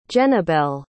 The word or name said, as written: jennabel